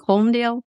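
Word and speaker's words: Holmdel: Holmdale? – You did.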